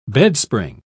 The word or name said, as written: bedspring